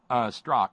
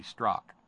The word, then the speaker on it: Strzok: Strzok, Strzok, Strzok. – Strzok. – Silent okay. – Z.